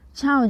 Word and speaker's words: Ciao: 它的拼法是 C I A O，Ciao。各位要注意哦，这个 C 的发音很像是注音的七，所以呢，整个呃就是发音应该是 Ciao。 Ciao 就是打招呼，Hello 的意思。